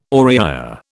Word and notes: Oriya: British English

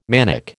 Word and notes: manic: American English

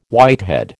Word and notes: whitehead: American English

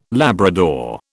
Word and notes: Labrador: British English

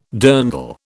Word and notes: dirndl: British English

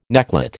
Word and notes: necklet: American English

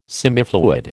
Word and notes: semifluid: American English

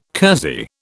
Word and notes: kersey: British English

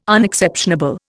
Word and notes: Unexceptionable: Indian

